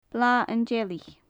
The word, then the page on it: blath an t-seilich: gaelic